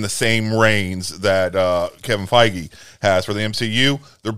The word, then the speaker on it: Feige: Is it Feige? – Feige.